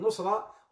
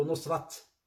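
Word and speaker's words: نصرة: And nusrah, or as they say in Urdu, nusrat. They pronounce the T at the end, nusrat.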